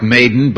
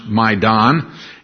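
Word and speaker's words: Maidan (Майдан): It looks like maiden, but it's pronounced Maidan. It's a Ukrainian word that means square.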